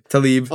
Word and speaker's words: Tlaib: How do you pronounce that last Tlaib. – Name? Tlaib. – Although it is spelled T-L-A-I-B.